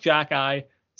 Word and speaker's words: Xhekaj: Pronounced Jacki, spelled Xhekaj.